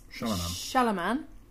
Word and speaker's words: Shyamalan: Shyamalan.